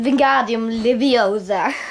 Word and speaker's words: Wingardium Leviosa: Ähm, Wingardium Leviosa.